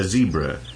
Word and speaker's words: zebra: Yes, a zebra. To rhyme with Deborah in Britain and Debra in America, once those countries exist.